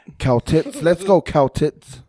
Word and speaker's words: Celtics: The C is a hard K sounding, not an S. – So it's pronounced the Celtics, Celtic. Yeah. But people call them the Celtics. – Okay, Celtics.